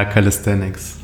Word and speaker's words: Calisthenics: Und das andere ist, ich werde es höchstwahrscheinlich nicht korrekt aussprechen können, aber es heißt Calisthenics. ist das richtig? – Ja, Calisthenics.